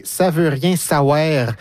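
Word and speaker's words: Saouère: Je dis bien « savoir », c'est parce que c'est appelé S-A-O-U-E, accent grave, R-E. Mm-hmm. Donc, c'est appelé de manière phonétique, comme on le prononce.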